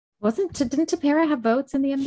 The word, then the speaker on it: Tepera: And Dusty, did you see the thing about Dusty not knowing who, is it Tepera? How do you say it's Tepera, right? Ryan Tepera's name. – Ryan Tepera, yeah.